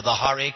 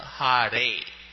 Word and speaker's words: Hare: I hear the Hare with the roll of the R. Do we roll the R? Do we not roll the R? – I don't roll it.